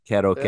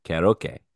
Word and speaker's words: karaoke: Karaoke. – Yeah. – It is funny. That is how you pronounce it over in Japan. It's karaoke. And it comes over to the States, and it's karaoke. But it's a karaoke bar.